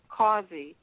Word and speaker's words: Causey: So it's pronounced Causey. But if you think of a good cause, like love or peace is a good cause, then and just add a Y to it, then you can find me.